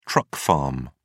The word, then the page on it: truck farm: British English